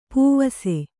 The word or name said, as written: ಪೂವಸೆ